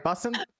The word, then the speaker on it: bussin': Did I say that right? Bussin'? – Bussin'. – Yeah. – Bussin'. All right, good.